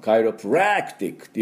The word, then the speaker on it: chiropractic: で、第二、アクセントですから、そんなに強く、カイロプラクティックっていうふうに言いませんので、カイロの方が、アクセントがありますね。このカイのところですね。で、カイロプラクティック。ちょっと軽めに。